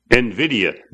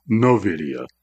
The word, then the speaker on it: NVIDIA: NVIDIA, not NOVIDIA.